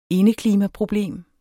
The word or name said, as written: indeklimaproblem